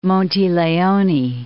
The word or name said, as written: Leone, Monte